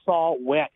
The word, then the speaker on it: saw-whet: The name saw Whet, and wet is spelled W H E T,